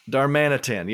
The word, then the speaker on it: Darmanitan: Darmanitan. He's kind of like Darmanitan, yes.